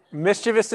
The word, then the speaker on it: mischievous: Many of you, it may not surprise to know that when I was a young child, I was a bit mischievous or mischievous. I don't know how you say it. Mischievous. You say mischievous? Any mischievous ones in here? Let's take a vote. Mischievous. Hold them high. Mischievous. Okay. Mischievous. Mischievous is correct.